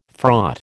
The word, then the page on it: fraught: American English